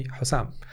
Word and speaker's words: Houssam: So all my friends don't call me Sam. They don't call me any other name. They call me Houssam.